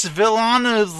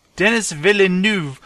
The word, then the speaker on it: Villeneuve: Denis Villeneuve. I can't say this guy's name. How do you say this? – Yeah. – Villeneuve. – Denis Villeneuve? – Villeneuve? Denis Villeneuve.